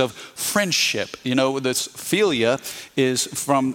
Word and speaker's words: philia: It's philia, philia? How do I say that? – Philia.